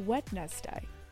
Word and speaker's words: Wednesday: So why is it Wednesday? Interesting. – Why is it a silent D and not the H?